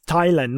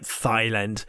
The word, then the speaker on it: Thailand: So it's Thailand, not Thailand. Thai is a different thing. Never mind. Uh, but the good thing about this is in Thailand, yeah.